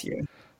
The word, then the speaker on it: Växjö: So let's move ahead and move on to, is it Växjö? Växjö? How do you pronounce Växjö? – Right, I won't say that anymore.